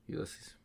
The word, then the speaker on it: Ulysses: Maybe it's Ulis. I-U-L-I-S. – Is it by James Joyce? – I don't... – It's Ulysses. – Ulysses.